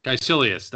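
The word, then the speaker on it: Kaecilius: introduce major characters Dr. Stephen Strange, Karl Mordo, Christine Palmer, Wong, uh, K- K- K- boy, Kelsey Leos. How do you fucking pronounce that name? Caecilius. Caecilius, that's it.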